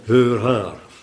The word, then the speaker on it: heur haar: In Oud-Nederlands, zuster, ik zweer het je, schrijf je dat zo en spreek je dat zo uit. Heur haar. Net als 7-7-9.